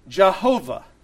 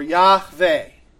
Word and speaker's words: Yahweh: It is a four letter word, yod, hey, vod, hey, and those four letters we think would most properly be pronounced Yahweh. Or Yahweh, right?